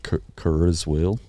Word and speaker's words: Kurzweil: Because there are futurists like Ray, I don't know if I get his name right here, Ray Kurzweil. Kurzweil, maybe some of our listeners could Kurzweil? – Correct. Kurzweil, maybe that's how you pronounce it. Mm. But it's K-U-R-Z-W-E-I-L. – Oh, wheel, sorry, Kurzweil. – Kurzweil. Kurzweil, maybe. – Sounds like it's German.